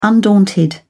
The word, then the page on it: undaunted: British English